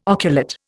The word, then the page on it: oculate: Indian